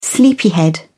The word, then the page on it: sleepyhead: British English